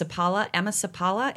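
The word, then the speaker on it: Emma Seppala: And I heard I'm obviously teaching happiness research in my classes that I'm teaching right now, but I think her name's pronounced Sapala, Emma Sapala. It's S-E-P-P. Is that right, John? – Yes, that's correct. – Okay, yes.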